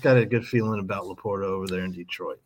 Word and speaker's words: LaPorta: Is Laporta? Is that how you say it for Detroit? The rookie tight end. I'm picking him up as much as possible late in drafts. – Sam Laporta.